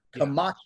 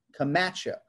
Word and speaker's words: Camacho: Like it doesn't bother me when you say Frank Camacho at all, the way it bothers Lappy, as long as you're emphasizing that second syllable. Camacho, yeah. Camacho.